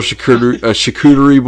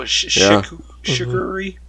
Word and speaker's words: charcuterie: So, charcuterie. I call it charcuterie. I don't do the R. I skip over that R.